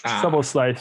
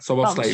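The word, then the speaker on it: Szoboszlai: Ee, evet ee, bu arada şu e, hemen mute alıp e, teyit ettirdim. Soba diye okunuyormuş. Soba slide, süper. – Soboslay.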